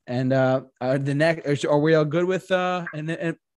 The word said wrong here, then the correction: Enema: How do you say it? I'm sorry. – Enema. – Enema of the state.